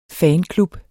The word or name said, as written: fanklub